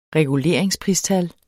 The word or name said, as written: reguleringspristal